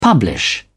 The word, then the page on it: publish: British English